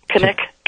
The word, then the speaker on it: Knik: Kinnick, K-N-I-K, Arm. Kinnick Arm Bridge.